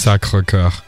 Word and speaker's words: Sacré-Cœur: That's pronounced in French. – Sacré-Cœur.